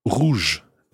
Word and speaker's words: rouge: Let's take rouge now. Rouge meaning red. Now, rouge is spelt R-O-U-G-E. The E at the end helps the J sound of rouge stay soft.